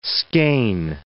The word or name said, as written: skein